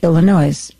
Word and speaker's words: Illinois: That's how you say it, Illinois. Just ask anybody.